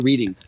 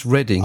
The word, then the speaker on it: Reading: And that's how I pronounce it over here. Is that correct, saying Reading, or is it Reading? – No, you've got it right the first time. It is Reading, yes.